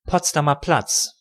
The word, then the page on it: Potsdamer Platz: German; [potsdamaː plats]